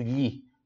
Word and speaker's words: gli: GLI é já expliquei antes, som de LHI, por isso li.